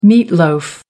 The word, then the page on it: meat loaf: American English